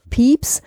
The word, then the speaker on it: Pepys: Jahrhundert, nämlich Samuel Pepys ähm, geschrieben P-E-P-Y-S, also spricht man ein bisschen anders aus, als man es äh, schreibt.